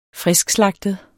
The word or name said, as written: friskslagtet